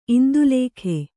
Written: ಇಂದುಲೇಖೆ

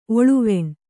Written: ಒಳ್ವಣಿ

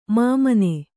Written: ಮುಮ್ಮನ

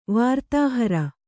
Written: ವಾರ್ತಾಹರ

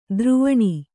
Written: ದ್ರುವಣಿ

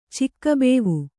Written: ಚಿಕ್ಕಬೇವು

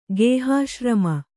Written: ಗೇಹಾಶ್ರಮ

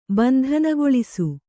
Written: ಬಂಧನಗೊಳಿಸು

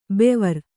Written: ಭವಾರಿ

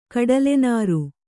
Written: ಕಡಲೆನಾರು

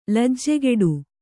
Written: ಲಜ್ಜೆಗೆಡು

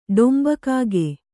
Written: ಡೊಂಬಕಾಗೆ

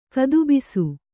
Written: ಕದುಬಿಸು

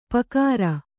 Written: ಪಕಾರ

